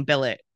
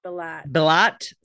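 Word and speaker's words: Brillat: Well, and I really want to call him Billet because that's my sister's last name. So it's really hard, but it's B-I-L-L-A-T.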